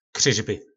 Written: kryzby

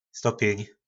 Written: stopjyn

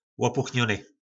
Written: lopuchnony

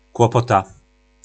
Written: klopota

